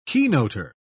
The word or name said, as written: keynoter